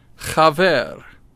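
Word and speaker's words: Chaver (חבר): Chaver. That's where you've got to do something a little bit with your throat. Chaver. All right, it's spelled C-H-A, but don't say chaver. It's chaver.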